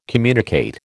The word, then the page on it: communicate: American English